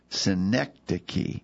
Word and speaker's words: synecdoche: I hear this pronounced different ways, but I think the proper pronunciation is synecdoche. Synecdoche. Synecdoche. Sometimes I hear people pronounce it synecdoche, and I don't think that may be an acceptable alter pronunciation. But the best of my knowledge, it should be synecdoche.